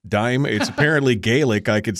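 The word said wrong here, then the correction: Dàimh: It says dive. The MH in Gaelic is pronounced like a V in English, and it's the name of a Scottish band that I'm a big fan of, have gone to festivals to see.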